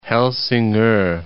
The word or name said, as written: Helsingor